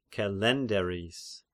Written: Kelenderis

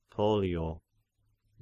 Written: Pollio

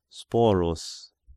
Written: Sporus

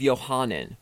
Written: Johanan

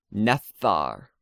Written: nephthar

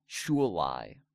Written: Shullai

